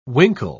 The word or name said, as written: winkle